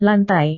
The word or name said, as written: lantai